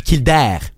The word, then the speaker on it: Kildare: Kildare. La vraie prononciation, c'est Kildare.